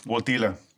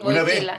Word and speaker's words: Wojtyla: Llamado por la curiosidad, un joven sacerdote, Karol Wojtyla, ¿cómo lo pronuncio? – Wojtyla. – Bueno, tú que hablas polaco, te pregunto. – Mira, déjame decirte que la W es como una B. Wojtyla.